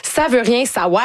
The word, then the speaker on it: Ça veut rien savoir: Ça veut rien savoir. Vraiment, ça s'écrit de même, faut le prononcer comme ça.